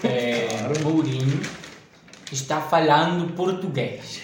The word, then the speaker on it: Mourinho: Estaba Mauriño en el Madrid. Mauriño. – Que tiró 2014. – Mourinho. – Mourinho, Mourinho. – Mourinho, perdón, perdón.